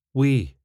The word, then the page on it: wee: スコットランド英語